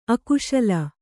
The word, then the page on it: ಅಕ್ಕಸಾಲ: Kannada